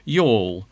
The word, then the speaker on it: Youghal: Now that's a price you probably won't forget in a hurry, and it's also a little reminder of how to pronounce that Irish provincial town, Youghal, as in boat.